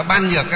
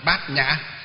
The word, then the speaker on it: Bát nhã: Khi mà chúng ta đọc là ban nhược á thì là không phải, mà phải phiên âm là gì? Đọc là gì? Bát nhã.